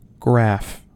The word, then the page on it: graph: American English